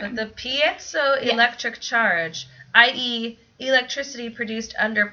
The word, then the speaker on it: piezoelectric: Creates a piezoelectric charge. – Mm-hmm. Well, that's how I would have gone. That's how I would have pronounced that. A piezoelectric charge.